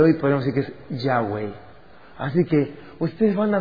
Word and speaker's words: Yahweh: Y salía sonando algo como Yahweh. O ya, en griega, en español, Yahweh. Si lo pronunciamos en español. En inglés suena como Yahová. Y de ahí es donde deriva la voz que en algunas versiones de Biblia sale como Yahvé, y en otras sale como Jehová.